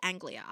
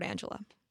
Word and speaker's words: Anglia: Is it Anglia or Angela? – I pronounce it Anglia. I pronounce it a Ford Anglia. But if I'm saying it wrong, we can all blame my father who read me the books out loud and pronounced it a Ford Angela.